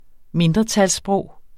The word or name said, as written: mindretalssprog